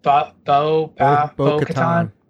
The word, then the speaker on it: Bo-Katan: Was it Bo-Katan,